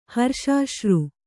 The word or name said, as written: ಹರ್ಷಾಶ್ರು